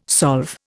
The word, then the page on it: solve: Indian